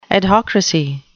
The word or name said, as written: adhocracy